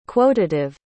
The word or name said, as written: quotative